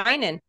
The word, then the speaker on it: Heinen: Yeah, Heinen.